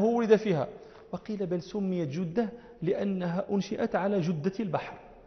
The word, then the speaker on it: جدة: وفي مثل ما بين مكة وجدة، وجدة أيضا مدينة قديمة، والفصيح أن يقول الناس جدة بضم الجيم، لا يقولون جدة، نحن نقول جدة، وأهل جدة يقولون جدة فيكسرونها، وكل لحن، والصواب كما نطق الموطأ جدة.